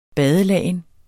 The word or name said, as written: badelagen